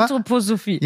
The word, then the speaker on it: Anthroposophie: Und ansonsten, was mir nochmal einfiel, das habe ich mal in einem anderen Podcast gesagt, aber jetzt, wo wir so Anthroposie und so, nee, ich habe es falsch ausgesprochen, aber ich spreche Sachen immer falsch aus. – Genau. – Du lachst dich jetzt schon kaputt, ja. – Aber, Anthroposophie.